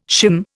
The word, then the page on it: shim: Indian